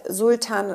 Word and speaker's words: Sultan: Äh, mein Name ist Sultan, ähm, es wird halt Sultan in Deutschland ausgesprochen.